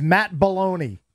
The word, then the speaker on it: Belloni: His name's Matt Baloney. – B-E-L-L-O-N-I. – Okay. I mean, that sounds like how you'd pronounce it, right? – Sure.